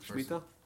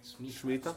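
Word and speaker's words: Shmita (שמיטה): Shmita. – And what are you saying? – Shmita. – Smicha. – And shmita. – Okay. – Say it slower. – Smicha. Shmita. – Shmita and shmita.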